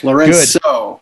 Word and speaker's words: Lourenco: Yes, Lourenco.